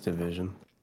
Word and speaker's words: DVSN: And then for music, I just saw Division on there. I think that's how you pronounce it, DVSN. – Yeah, it's Division.